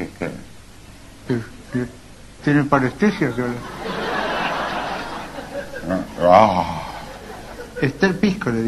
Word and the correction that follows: Terpsícore: dije. – No dije Esther. – ¿Cómo que no dije? – Dije, es Terpsícore. – Claro. Es Terpsícore. Es Terpsícore. Esa es la pronunciación griega, claro.